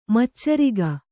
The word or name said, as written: ಮಚ್ಚರಿಗ